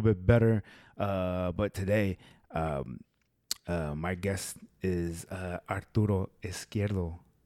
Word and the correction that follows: Izquierdo: It starts with an I. In the Spanish, it's pronounced E. – So, Izquierdo. Uh-huh. There you go. Izquierdo.